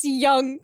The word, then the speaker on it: Jung: It says J U N G typology. – But it's young.